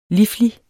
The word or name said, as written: liflig